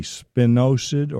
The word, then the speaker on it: spinosad: That's the active ingredient in the product, and it's S-P-I-N-O-S-A-D, spinosad or spinosad, some people pronounce it.